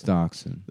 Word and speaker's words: Dachshund: W-E-B Deschound. How do you say that? – It's Dachshund.